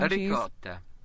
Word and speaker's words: ricotta: There's a little extra syllable on all the words. – It sounds arigata. More like a G when you hear ricotta.